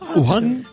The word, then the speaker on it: Wuhan: Se pronuncia Wuhan, porque Wuhan. La W no se pronuncia en chino.